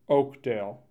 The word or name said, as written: Oakdale